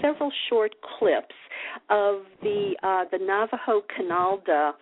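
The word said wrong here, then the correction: Kinaaldá: Am I saying that right? Canalda ritual? – It's actually Kinalda.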